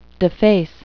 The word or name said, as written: deface